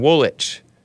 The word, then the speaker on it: Woolwich: It's Peckham Woolwich. The second W is silent, according to Richie, if he's to be believed, which I would assume he would have good intentions.